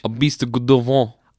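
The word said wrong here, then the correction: Gévaudan: I'm saying it wrong. Beast of Gavodon.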